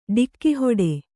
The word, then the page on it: ಟಕ್ಕುಹಿಡಿ: ṭakku hiḍi